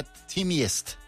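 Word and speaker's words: Teamgeist: Sí, Tim Geist o Geist. – Se pronunciaría Timiest.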